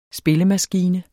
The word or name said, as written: SPILLEMASKINE